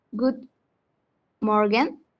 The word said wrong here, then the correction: guten Morgen: guten morgen. – guten morgen. – Ah, ah, guten morgen. Ya, richtig. Bien.